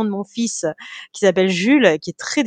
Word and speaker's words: Jules: comment ils disent Jules Roule euh, non, non, non, c'est plus jules Joule, alors la, prononciation vraiment littérale, ce serait Youless, ce qui est un petit peu très très loin de mon Jules.